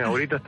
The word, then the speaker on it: auritas: No sé si lo digo bien, auritas. – Sí, lo dices muy bien, auritas también.